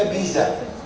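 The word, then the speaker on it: pizza: سالت انا عليها طبعا، قالوا لي دي اللغه الايطاليه، وفي الايطالي ذات ذات تحط فيها تي في النطق. يعني تكتب بيتزا، لكن بتيجي تقول ايه؟ بيتزا. فاللغه الايطاليه تحط تي في النص لو بتبدا اتنين زاد ورا بعض.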